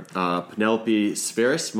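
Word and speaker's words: Penelope Spheeris: The chronicler, Penelope Spheris.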